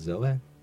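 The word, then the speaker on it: Zoë: That's spelled with an E umlaut, and that's why it's pronounced Zoe.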